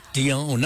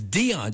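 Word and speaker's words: Dionne: I always used to say Dion. No, it's Dion, just like Dion and the Belmonts.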